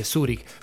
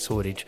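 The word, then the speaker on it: Zürich: Si llegan a tener oportunidad de viajar a la ciudad de Zurich, que Rafael obviamente lo pronuncia bien en alemán y dice Zurich, pero si no se dice para nosotros los argentinos es Zurich.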